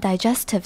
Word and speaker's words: digestive: 那前面 digestive 这个 di 是 die 是长母音，接下来 ges 虽然自然发音法会变成有点像 guess 的感觉，但是它这边是 digest 是 jess 的音，所以 digestive， digestive。